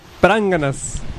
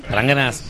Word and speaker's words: pránganas: Ok, bola de pránganas. ¿Ahora sí lo dije bien? – Pránganas, perfectamente bien dicho.